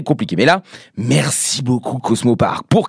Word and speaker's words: Cosmo Paark: Bah oui, pour être précis comme ça dans la musique, hein, du nom de Cosmo Park. Alors bon, pourquoi je le dis comme ça? Ça s'écrit C-O-S-M-O-P-A-A-R-K.